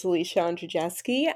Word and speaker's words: Alicia Andrzejewski: So as you pronounce beautifully, my name is Alicia Andrzejewski.